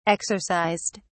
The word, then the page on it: exercised: British English